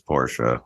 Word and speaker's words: Porsche: Some people say Porsche. – Porsche. – The guy's name is Porsche, so that's how it should be pronounced.